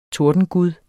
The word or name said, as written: TORDENGUD